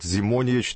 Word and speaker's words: Зимонич: меняет Ненад Зимонич, так, как выясняется, правильно произносить фамилию этого теннисиста.